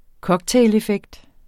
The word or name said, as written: cocktaileffekt